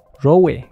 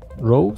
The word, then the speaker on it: Rowe: Bueno, esta película ha sido dirigida por Jeff Rowe. Rowe. Rowe se pronuncia.